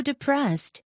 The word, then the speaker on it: depressed: Don't be so depressed. – 자, 들었니 얘들아? Don't be so depressed. Depressed. 끝에 두 발음 아주 살짝 났고요.